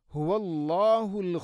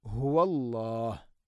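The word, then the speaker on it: ഹുവല്ലാഹ് (هو الله): അടുത്ത ആയത്ത്, ഹുവല്ലാഹുൽ ഖാലിഖുൽ ബാരിഉൽ മുസവ്വിർ. ഹുവല്ലാഹ്, നേരത്തെ പറഞ്ഞതുപോലെ ഹ എൻ്റെ ഉച്ചാരണം ശ്രദ്ധിക്കണം, തൊണ്ടയുടെ താഴേ ഭാഗമാണ് ഹ. ഹുവല്ലാ, ഹുവല്ലാ എന്ന് പറയരുത്.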